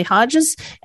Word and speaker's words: Hodges: We're talking to Romilly Hodges. Hodges or Hodge, do you say your last name? – It's Hodges.